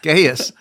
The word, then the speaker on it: Gaius: And the first one you're going to be talking about today, and I'm going to try to pronounce this right, is Gaius. Is that correct? – That's totally wrong. Totally wrong. No, that's right. Gaius.